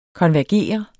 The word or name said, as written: konvergere